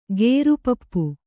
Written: ಗೇರುಪಪ್ಪು

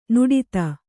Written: ನಾಡಿದು

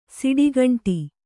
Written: ಷಟ್ಖಂಡ

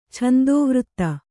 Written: ಛಂದೋವೃತ್ತ

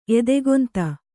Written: ಎದೆಗೊಂತ

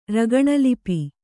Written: ರಗಣಲಿಪಿ

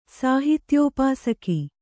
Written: ಸಾಹಿತ್ಯೋಪಾಸಕ

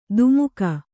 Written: ಧಿಮಾಕು

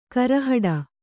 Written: ಗುರಿಹೊಡೆ

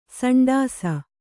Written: ಸಂಡಾಸ

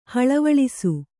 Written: ಹಳವಳಿಸು

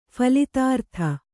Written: ಫಲಿತಾರ್ಥ

